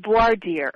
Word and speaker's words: Voir Dire: Another thing I see here is Voir Dire. Did I pronounce that correctly? – I think it's Voir Dire. – Voir Dire.